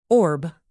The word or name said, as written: orb